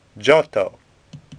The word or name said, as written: Giotto